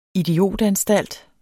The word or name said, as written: idiotanstalt